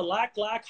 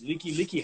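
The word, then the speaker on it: Likelike: Is that the Lack Lack Highway? And he's like, no, it's Leaky Leaky Highway.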